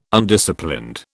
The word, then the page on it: Undisciplined: British English